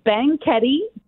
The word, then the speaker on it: Banquette: So it's Banquette, Texas. It's supposedly, it's a derivation of the word banquet.